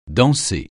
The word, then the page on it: danser: francais